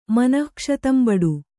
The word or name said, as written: ಮನಃಕ್ಷತಂಬಡು